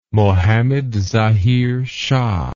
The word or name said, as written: Mohammed Zahir Shah